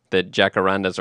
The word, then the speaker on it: jacarandas: So the enjoying the jacarandas, or we can say jacarandas. I'm okay pronouncing that J like an H on this podcast.